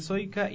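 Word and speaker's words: Sojka: Soika es S-O-J-K, por si acaso. La J se pronuncia como I en checo.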